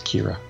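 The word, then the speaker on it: Qi'ra: Yeah, but it's spelled with a Q, but it's Kira.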